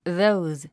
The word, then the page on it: those: ðóuz; ドーズ